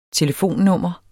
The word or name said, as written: telefonnummer